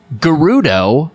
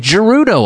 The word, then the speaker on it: Gerudo: I didn't realize Gerudo was said with a hard G. I've been saying it Gerudo all these years.